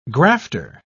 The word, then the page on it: grafter: British English